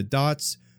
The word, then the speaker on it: Dott: So of course, it's called Connecting the Dots. My last name is Dot, D-O-T-T, which is why Dots is spelled with two T's in the name of the podcast.